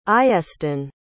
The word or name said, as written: iestyn